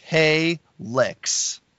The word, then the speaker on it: Halyx: They were called Halyx, H A L Y X, pronounced Hey Licks.